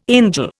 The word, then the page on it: angel: Indian